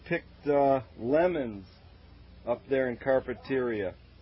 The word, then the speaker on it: Carpinteria: Oh, Carpinteria. – That's the Spanish. And the Spanish is penultimate syllable.